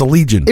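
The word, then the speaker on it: Allegaeon: It's spelled weird, but it's Allegion.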